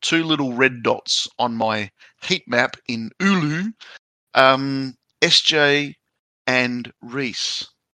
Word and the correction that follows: Oulu: Oh yeah, it's Oulu, not Ulu. – Thanks. – All right. Ulu. Noted. Noted. I'll slap myself on the wrist. – Is that Oulu spelled O-U-L-O? – It's O-U-L-U.